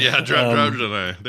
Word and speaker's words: Draenei: I was playing Dr- uh, Draenei. – Yeah, I'm saying that right. Yeah, Draenei.